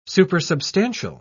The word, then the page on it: supersubstantial: British English